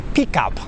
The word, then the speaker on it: pickup: Pickup in with Polish people is pronounced sometimes terribly. It's pronounced like this, pickup. It's not a pickup. Sounds like a totally different word. It's a pickup. Make that E, a U sound, pickup truck.